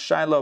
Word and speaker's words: Shiloh: As it is stated, the scepter shall not depart from Judah until Shiloh comes and all the nations will rally to him. I know it could be pronounced Shiloh, but I'm doing the Hebrew pronunciation.